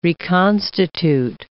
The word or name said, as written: reconstitute